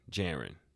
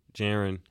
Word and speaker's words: Geryn: Uh, on Instagram, it's underscore G E R Y N. Garen. Jaren. Jaren Harris.